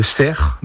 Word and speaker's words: cerf: Alors, le cerf, donc le F se prononce ici, je ne sais pas pourquoi, est vraiment dans toutes les déclinaisons, donc saucisse, barbecue, en salade.